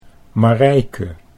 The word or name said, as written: Marijke